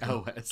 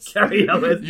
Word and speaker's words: Cary Elwes: Cary Elwes. – Cary Elwes. – You literally do just say it as it's written, which I've learned.